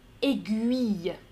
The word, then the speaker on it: aiguille: Euh, la prononciation correcte, c'est aiguille. Donc pas aiguille, aiguille. On entend le U.